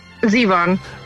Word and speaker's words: Zevon: if you would like to hear Warren Zebath, how do you say his name? – Zevon.